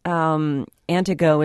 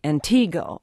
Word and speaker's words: Antigo: Antigo is another one. Locals will just omit the T altogether. I mean, first of all, it's wrong to say Antigo.